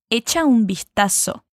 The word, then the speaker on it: vistazo: Echa un vistazo. Isso é. Mais uma vez e cuidado com a pronúncia do V do espanhol, hein?